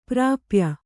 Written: ಪ್ರಾಪ್ಯ

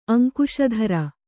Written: ಅಂಕುಶಧರ

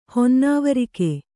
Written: ಹೊನ್ನಾವರಿಕೆ

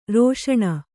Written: ರಾಸನಾ

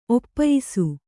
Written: ಒಪ್ಪಯಿಸು

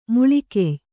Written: ಮೊಳಕಯ್

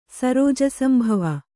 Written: ಸರೋಜಸಂಭವ

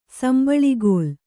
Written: ಸಂಬಳಿಗೋಲು

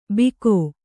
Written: ಬಿಕೋ

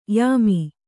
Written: ಯಮೆ

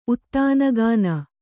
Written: ಉತ್ತಾನಗಾನ

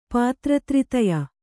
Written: ಪಾತ್ರತ್ರಿತಯ

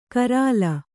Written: ಗರಲ

